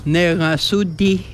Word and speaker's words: Negasudi: How do you say you are funny? – Negasudi. – Negasudi. – Negasudi. – Negasudi. – Yes, right on. – Negasudi. – That's it.